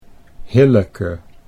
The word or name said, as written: Hilleke